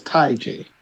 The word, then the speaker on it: TayJay: It's spelled TJ, but her name is Ty. So it's Ty J. So, which is stupid. – Yeah, it's stupid. Just put TJ. – T-A-Y-J-A-Y. – Okay.